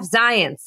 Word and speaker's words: Zients: So who is Jeff Zients? And yes, that is how you pronounce his last name.